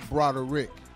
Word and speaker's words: Broderick: It's actually Broderick. – Broderick. – It's 3 syllables.